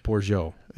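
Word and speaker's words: Bourgeault: No, Borgialt. – E-A- The T is silent in French.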